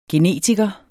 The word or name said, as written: genetiker